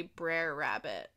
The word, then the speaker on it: Brer Rabbit: These are spelled like B-R apostrophe E-R. – Yeah, so I think it's short for brother. So I think it's supposed to be Brer Rabbit.